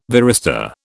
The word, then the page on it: varistor: British English